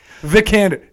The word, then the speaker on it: Vikander: Alicia Vikander. All right. – Vikander. Say it right. – Vikander. – There you go.